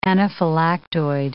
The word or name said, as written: anaphylactoid